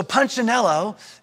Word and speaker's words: Punchinello: And there's this one particular Wemmick called, let me make sure I say it right, Punchinello. Punchinello. See, I see even say it wrong. My son corrects me all the time. He's like, Daddy, it's Punchinello.